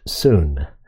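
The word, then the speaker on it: soon (σύν): And this little prefix is the word pronounced soon, and it means simply with.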